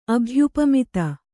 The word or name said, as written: ಅಭ್ಯುಪಮಿತ